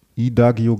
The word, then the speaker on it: Idagio: I-D-A-G-I-O. – Idagio geschrieben? – So sieht's aus.